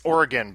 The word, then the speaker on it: Oregon: It's Oregon, by the way. You got to say it correctly.